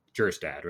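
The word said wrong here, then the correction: Kjerstad: right? No, Kerstad. The J is silent.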